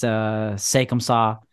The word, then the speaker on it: C'est comme ça: And it's a French title. It's pronounced C'est comme ça.